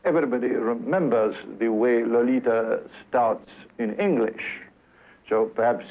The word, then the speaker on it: Lolita: Lolita. Now comes the rush. – Note, by the way, that the first syllable of her name sounds more like an R sound than the O sound. Lalita, свет моей жизни, огонь моих чресел, грех мой, душа моя, Лалита.